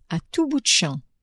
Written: a tout bout de champ

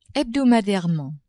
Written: hebdomadairement

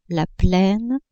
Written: plaine

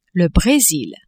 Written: Bresil

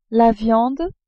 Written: viande

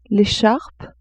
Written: echarpe

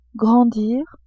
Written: grandir

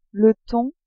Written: thon

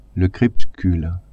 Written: crepuscule

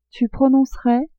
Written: tu prononcerais

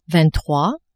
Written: vingt-trois

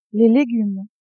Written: legumes

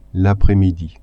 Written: apres-midi

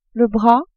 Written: bras

